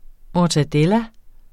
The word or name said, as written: mortadella